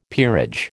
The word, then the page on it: peerage: American English